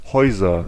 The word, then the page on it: Häuser: /ˈhɔʏ̯zɐ/; /hɔɪ̯zɐ/